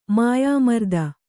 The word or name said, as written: ಮಾಯಾಮರ್ದನ